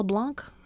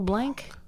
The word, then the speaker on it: Blanc: Was it pronounced Blanc? Mel Blanc? Mel Blanc? – I think it's Blanc. – I feel like it might be. – No, I think people say Blanc.